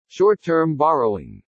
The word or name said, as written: SHORT-TERM BORROWING